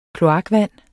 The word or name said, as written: kloakvand